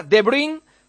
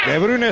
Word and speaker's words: De Bruyne: De Bruyne, bien pronunciado por Oliveros. – De Bruyne está perfilado también.